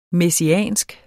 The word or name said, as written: messiansk